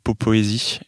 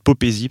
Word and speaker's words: popésie: popoésie, mais non, c'est popésie, parce que le principe, c'est le mot poésie dans lequel j'ai rajouté un P.